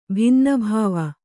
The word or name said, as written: ಭಿನ್ನಭಾವ